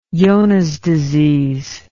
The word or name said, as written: Johne's disease